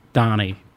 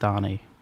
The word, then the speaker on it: Dani: And my first name is Donnie, but spelled D-A-N-I. – Okay. – Pronounced Donnie.